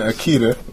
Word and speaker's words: Akita: And Akita, that's how you pronounce it.